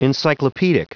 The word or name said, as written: encyclopaedic